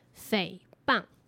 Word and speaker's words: 诽谤: 这两个字正确的念法是“ 诽谤”。